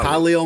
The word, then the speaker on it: Khalil: But like the running back, for example, Herbert, his name is like, typically when you see that name, you say Khalil. I think that's like, if there's a common pronunciation of that, of K-H-A-L-I-L, it's probably Khalil.